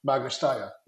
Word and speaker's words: Magna Steyr: So, for example, thinking of Magna Steyr, like, would it make sense to try to, by the way, am I finally pronouncing that correctly? – Yes, perfect.